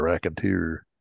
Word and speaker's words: raconteur: It's raconteur.